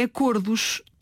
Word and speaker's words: acordos: Bom, Sandra, o plural da palavra acordo é acordos, tal como lobo ou lobos, não é? Lobo-lobos, acordo-acordos. Ou será acordos, tal como olho e olhos? – Ora bem, é uma boa dúvida, Filomena.